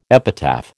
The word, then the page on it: epitaph: American English